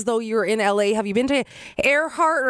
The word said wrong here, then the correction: Erewhon: or how do you say that fancy grocery store? Have you tried? – Oh, Erewhon? – Yeah, have you tried the Hailey Bieber smoothie?